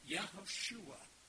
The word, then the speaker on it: Yehoshua: Now, they spoke Aramaic at the time of Jesus, but if someone would have pronounced his name in Hebrew, it would have been Joshua. Or it would have been Yehoshua, just like the original Joshua.